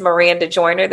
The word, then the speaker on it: Maranda Joiner: My name is Maranda Joiner. That's MarandaJoiner, spelled with all A's in the first name.